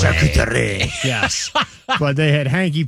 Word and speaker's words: charcuterie: I had one of those charcuterie boards. Am I saying that right? – Yeah.